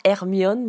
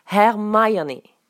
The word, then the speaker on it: Hermione: Et ça ne, se prononce, pas Hermione, mais Hermione.